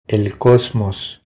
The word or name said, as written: El cosmos